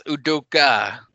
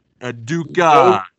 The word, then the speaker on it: Udoka: Adoku Azubuike. How'd I do? – Pretty dang close. It's Udoka. – Udoka. – Udoka Azubuike.